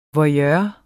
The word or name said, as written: voyeur